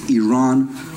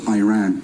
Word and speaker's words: Iran: You're pronouncing our country's name wrong. Our country is named Iran, not Iran.